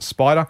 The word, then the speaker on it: SPDR: SPDR, you'll see, so they pronounce it Spider.